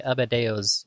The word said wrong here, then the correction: Adebayo: name? Adebayo. – I wrote it down as Adebayo. – Yeah, Adebayo. That sounds better.